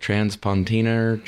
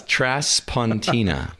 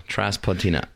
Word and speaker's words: Traspontina: Everybody's been mispronouncing it. They're like Traspontina, transportation. – Transportation. – Yeah, no, it's Traspontina. Traspontina.